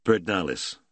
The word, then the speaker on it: Pedernales: And he pronounced the name as Texans did, "Pedernales."